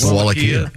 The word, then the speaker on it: Wallachia: Scientists have discovered that the prince of, how do you pronounce this, Wallachia, probably did not eat meat. That was... – That's where you have to put your steaks together. – The Wallachia place? – Wallachia.